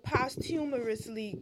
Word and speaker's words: posthumous: Well, I would have posthumously got your... Oh, bitch, you keep fucking saying it wrong on purpose. – Post... What is it? Posthumous. – Listen to the white lady fucking pronounce it.